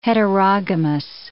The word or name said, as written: heterogamous